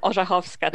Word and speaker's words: Orzechowska: Orzechowska, to be exact.